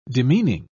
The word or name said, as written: demeaning